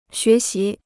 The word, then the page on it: 学习: xué xí